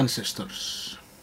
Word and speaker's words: Ancestors: pronúncialo tú, que pronuncias mejor. – Ancestors. – Vale, pero eso no se entiende. Ancestors. Ancestors. O sea, A-N-C-E. Y va en letra. S-T-O-R-S.